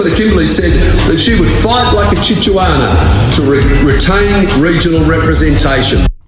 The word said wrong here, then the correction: Chihuahua: It's pronounced Chihuahua. Chihuahua. So what we'll do, we'll give him a buzzer, a wrong buzzer, every time he says Chihuahua wrong. Here we go.